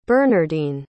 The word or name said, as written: bernardine